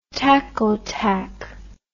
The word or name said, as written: tacautac